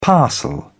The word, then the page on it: parcel: British English